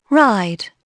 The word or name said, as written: ride